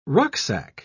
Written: rucksack

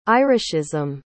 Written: irishism

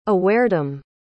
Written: awaredom

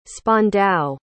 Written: spandau

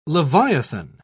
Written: leviathan

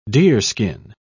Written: deerskin

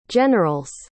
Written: general's

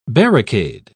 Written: barricade

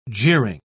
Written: jeering

